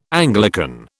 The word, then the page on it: Anglican: British English